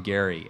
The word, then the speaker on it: Gerry: I'm told he pronounced his name Gary, actually, but it doesn't sound as good.